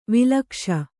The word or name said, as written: ವಳಕ್ಷ